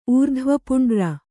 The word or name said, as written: ಊರ್ಧ್ವಪುಂಡ್ರ